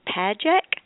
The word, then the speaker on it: Padgett: And by the way, her name is Annie Padgett. And the way to remember how to say that is it rhymes with magic. With a P.